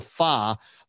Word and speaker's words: Fa: Fa, F A, that's how you say it. Uh, not the FA Cup in England for our buddy uh, David Payne, the boxing writer, and all the Brits on the soccer.